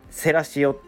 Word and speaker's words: Thrasio: で 3300 円の価値のある企業にしたっていうことですごいですよねでその企業の名前は何て言うんやっていう話なんですけどもセラシオっていうみたいです綴りが、えー、 THRASIO でセラシオと読むみたいです。